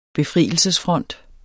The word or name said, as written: befrielsesfront